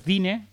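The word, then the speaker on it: Diné: Ahí está, pronunciado Dine, no.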